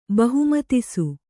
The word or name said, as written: ಬಹುಮತಿಸು